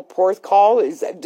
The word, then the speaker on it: Porthcawl: Your favorite golf course is that Royal Porthcawl? Did I pronounce that correctly? – Absolutely. Absolutely.